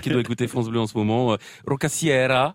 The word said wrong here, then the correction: Rocassiera: Rocossiero, d'ailleurs, parce que les A se prononcent O ouvert en occitan.